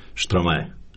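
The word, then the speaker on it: Stromae: Evo, ove godine me najviše dojmio Štromaje. Aha, se tako izgovara. – Dilema imen uvijek, ali recimo da je tako.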